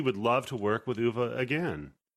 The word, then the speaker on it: Uwe: Uwe.